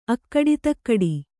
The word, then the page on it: ಅಗಡದಿಗಡ: kannada